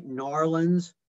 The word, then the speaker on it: New Orleans: And did I say that right? New Orleans? – You did. Great job. Okay, good.